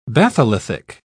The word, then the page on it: batholithic: British English